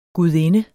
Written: GUDINDENS

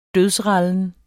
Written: dødsrallen

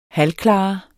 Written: halvklar

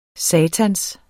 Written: satans

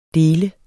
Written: del

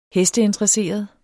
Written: hesteinteresseret

